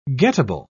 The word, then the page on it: gettable: British English